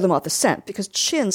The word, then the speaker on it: Chin: And we don't know if Chin, spelled C-H-I-N-N, is really our last name, because he used C-H-I-N, but Americans pronounce it Chun, or there's different intonations, so we think it's spelled C-H-I-N.